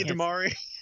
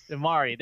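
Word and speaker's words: DeMarre: This is that small forward position, getting DeMar Carroll. Did I say that right? DeMari Carroll. – Just say DeMari. – DeMari. There we go.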